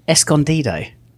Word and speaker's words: Escondido: We are in Escondido. Is that how you say it? – That is. It's pretty close.